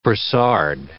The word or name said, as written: brassard